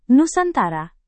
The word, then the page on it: nusantara: Indonesian